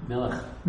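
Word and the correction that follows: melach (מלח): No. That's not how you say. – Melech. – No, melach. – Melach. – Melech and melach are two different words.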